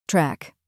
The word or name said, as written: track